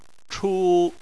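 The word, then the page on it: chu: tone 1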